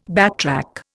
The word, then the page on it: backtrack: Indian